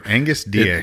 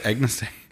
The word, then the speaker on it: Agnus Dei: After that, we say the Agnus Dei, which I always pronounce the G wrong. Apparently, I don't really know what it is, but and that's the when we're Agnus Dei. – Agnus Dei, Lamb of God, you take away the sins of the world.